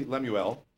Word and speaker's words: Lemuel: The prophecy, Lemuel, that's how you're supposed to say it.